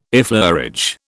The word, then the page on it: effleurage: British English